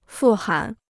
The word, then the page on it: 富含: fù hán